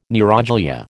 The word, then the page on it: neuroglia: American English